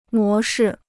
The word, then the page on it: 模式: mó shì